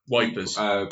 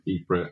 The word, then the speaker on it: Ypres: About the Battle of Ypres. Wipers. – Ypres. – Because the British soldiers like us can pronounce Ypres.